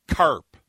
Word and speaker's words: Kharp: And I believe it's pronounced CARP, K-H-A-R-P.